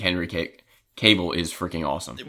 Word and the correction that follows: Cavill: Well, you have Cavill, travel, Cavill. That's how you. Cause I watch things like it's just pronounced travel, Cavill, Henry Cavill.